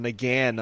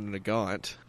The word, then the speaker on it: Nagant: Well, I mean, it's also, you know, a Nagan, not a Nagant. – Which, speaking of, they had a actual numbers matching sharpshooter model Nagant, which was pretty fucking cool.